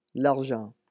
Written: l'argent

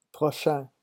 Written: prochain

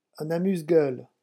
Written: un amuse gueule

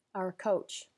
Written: our coach

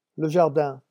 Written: le jardin

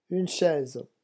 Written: une chaise

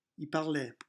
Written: il parlait

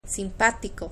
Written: simpático

